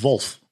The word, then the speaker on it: Wolf: And then we've got Wolf, which I think is the correct pronunciation for a German brand, Wolf. – How's it spelled? – W-O-L-F. – Really? Wolf. Yeah. – Wolf. – As in the Afrikaans word, Wolf, yeah, or English absolutely.